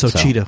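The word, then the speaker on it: Ouachita: All right, Roland was charged with theft and narcotics possession, both felonies, and booked into the Achua Parish Jail. I said that wrong. – I wouldn't know how to say it right. – It's Ocheeta. So. Ocheeta Parish Jail.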